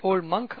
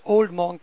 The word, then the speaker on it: Old Monk: Some people might say Old Monk. Ah, forget it. That's not how we pronounce it in India. It's Old Monk.